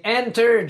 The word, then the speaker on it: Enter: Ouais, il est hanté. – Comment ça? – J'ai malencontreusement pesé sur le bouton hanté. – C'est Enter, Jerry. – Peu importe comment tu le prononces, j'ai pesé dessus, puis les données sont éparpillées sur le web.